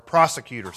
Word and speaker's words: prosecutor: Because this first section, verse 31 through 34, I have under the title or the point, persecutor, what are the charges? Or prosecutor, sorry, I didn't pronounce it right. Prosecutor.